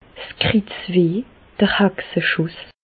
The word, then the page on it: 's Kritzweh, d'r Hexeschùss: Alsatian; Bas-Rhin; Reichshoffen